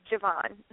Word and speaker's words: Javon: Yes, Javon. – Okay. Just making sure because my name is Tanya, and it's spelled T-A-N-Y-A, and most people like to pronounce it the American, you know, way, which is Tanya.